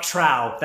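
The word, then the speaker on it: trou: Drop trow. That's how you pronounce it.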